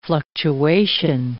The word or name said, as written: fluctuation